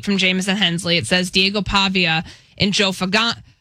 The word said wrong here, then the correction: Fagnano: the quarterback from UConn. I promise I'll get his name right. – Fagnano.